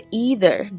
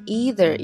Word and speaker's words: either: 首先呢，这个 either 这个词可以读成 either，也可以读成 either。那么在美国呢，这个 either 这个读音更常见，但是也有很多人会读 either。那么英国哪一个都有。